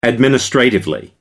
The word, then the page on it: administratively: American English